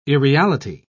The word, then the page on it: irreality: British English